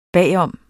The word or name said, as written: bagom